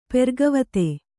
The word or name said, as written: ಪೆರ್ಗವತೆ